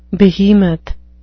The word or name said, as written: behemoth